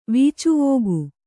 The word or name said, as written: ವೀಚುವೋಗು